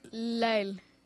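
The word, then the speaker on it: Lyle: Y L-Y-L-E. – Sí. Ahí está. Que tengo problemas para pronunciar eso. ¿Cómo es? – Lael.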